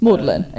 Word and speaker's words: Magdalen: I'm guessing they called her Maudlin, but it could be Magdalen. It's Maudlin. It is Maudlin. – As far as I know, they also pronounce it, well, slightly strangely as Maudlin, that is right.